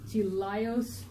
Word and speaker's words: teleios: So, and looking at this word perfect, in the Greek, it is Greek 546, which is teleios. It's spelled T-E-L-E-I-O-S.